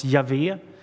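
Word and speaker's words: Jahve: Och då var det så att när de kom till Guds allra heligaste namn som stavas JHWH, det som vi tror ska uttalas Javé, så hoppade man över det namnet och så skrev man vidare.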